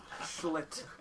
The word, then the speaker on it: Schlitt: Schlitt. S-C-H-L-I-T-T.